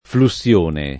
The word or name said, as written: flussione